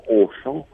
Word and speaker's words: Auchan: Вот в тот самый Auchan, да, который произносится как Auchan, там А и две буквы, с птичкой, да, вот там можно приобрести молоко и даже удивительно...